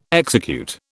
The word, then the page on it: execute: British English